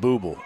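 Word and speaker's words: Bubel: I'm going with Bubble. Bubble? Bubble? It's really not a... Discussing with the public address announcer how to pronounce this catcher's name for Marion Military. It's B-U-B-E-L.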